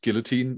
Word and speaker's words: guillotine: in English, guillotine, maybe, if you pronounce the L.